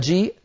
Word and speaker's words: جيء: السطر الأخير جاءت الهمزة متطرفة في آخر الكلمة يشاء قروء وجيء، لاحظوا بأن جيء الهمزة على السطر منطوقة والياء التي قبلها أيضا منطوقة، وأما الألف فزائد رسما مكتوبة هكذا كتبت بين يدي رسول الله ولكن لا تنطق هذه الألف.